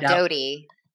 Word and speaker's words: Doute: Uh, speaking of mess, Kristen, how do you say her last name, Kristen's? – Uh, it Doute. – It's I always Doute. Wanna, I always want to call her Kristen Duty, but I know that's.